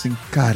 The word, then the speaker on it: Sin Cara: How do you say this? – Sin Cara. – There you go.